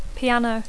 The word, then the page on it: piano: English